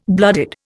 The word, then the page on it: blooded: Indian